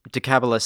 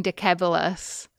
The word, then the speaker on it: Decebalus: Which is that you're pronouncing Decabalus with a hard C and I'm pronouncing it with a soft C, which might confuse people. – Decabalus. – In fact, is pronouncing it correctly, at least according to the rules that I gave out about Latin.